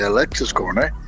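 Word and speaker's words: Alexis Korner: da un certo Alex Conner. È, è giusto questo Ronnie? – Giusto, eh, solamente la pronuncia del nome di, uh, uh, uh, di Alexis, eh, di Alexis Corner,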